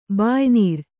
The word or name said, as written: ಬಾಯಿನೀರು